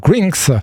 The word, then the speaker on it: Grnxx: Alors, ça s'écrit G-R-N-X-X. Ça se prononce donc Grinks.